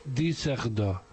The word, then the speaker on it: Düsseldorf: Es que Düsseldorf lleva la Düsseldorf, que se escribe, lleva dos puntos en la U. Y entonces dice Düsseldorf.